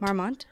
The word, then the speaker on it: Marmont: Yes, Marmont. Marmont.